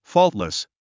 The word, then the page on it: faultless: fɔ́ːltlis; フォールトリス